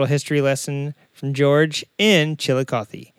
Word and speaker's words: Chillicothe: he says it sounds like chillicothe and the locals refer to the city as chili it's Ohio's first and third capital.